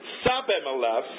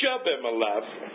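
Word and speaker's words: Shibboleth: If he said Sabimeleth instead of Shebimeleth, they knew that he was, because he could pronounce the word correctly, they seized him and killed him at the fords of the Jordan.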